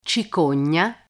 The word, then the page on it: cicogna: Italian